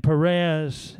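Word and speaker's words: Pharez: And Perez, it's spelled with an H, but the H is silent.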